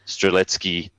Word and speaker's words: Strzelecki: But I love that genus actually, and I have a really distinct memory of being at the, and I'll have to pronounce this right because I have probably two Polish women sitting at home listening to this at the moment. So the Strzelecki Ranges, which I don't think is how most Australians pronounce it, same as Kosciuszko.